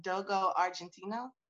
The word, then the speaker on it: Dogo Argentino: I've always wanted a Dogo Argentino.